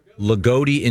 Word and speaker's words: Loogootee: L-O-O-G-O-O-T-E-E. – Ligoti? – Ligoti, Indiana. – Ligoti, Indiana.